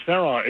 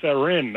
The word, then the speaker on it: Theron: And by the way, you can pronounce Charlize Theron. It's Theron, not Theron.